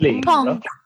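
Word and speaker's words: paon: Euh, qu'est-ce que c'est cet animal Comment on le prononce c'est un oiseau. – Pan. Pan. – A bit like Peter Pan. Uh, pan. C'est un oiseau.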